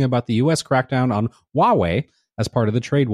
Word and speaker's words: Huawei: Look, I even got the name right. – Look, I was going to say, look at you. You said Huawei right, finally. – And I'm buzzed. As part of the trade war with China and all repercussions, it might. Maybe I have to be drunk to say it right.